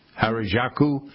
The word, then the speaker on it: Harajuku: How do you, it's spelled H-A-R-A-J-U-K-U. Harajuku.